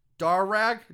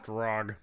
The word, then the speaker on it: Darragh: D-A-R-R-A-G-H. Darag? Darra? – Drog.